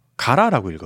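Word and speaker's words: 唐: 그 당나라 당을 쓰고 그 훈독으로 가라라고 읽어요.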